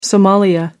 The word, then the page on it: somalia: American English